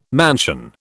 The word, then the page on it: mansion: British English